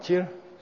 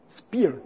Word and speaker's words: Chir: If you're from Gerong, like Gerong Kensa Rinpoche, it's spear. Okay? It's spear. Okay? Like in the outlying regions of Tibet, they still pronounce it probably the way it was originally pronounced. Spear.